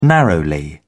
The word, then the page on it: narrowly: British English